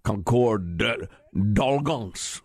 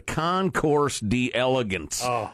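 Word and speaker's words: Concours d'Elegance: I've heard people lately trying to pronounce it properly in the French. Don't bother. Concorde d'Elegance, or whatever that's. It's the Concourse d'Elegance.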